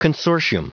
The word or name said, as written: consortium